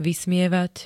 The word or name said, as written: vysmievať